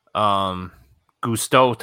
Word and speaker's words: Gusteau: But then when you come indoor, Gusteau talks to you, right?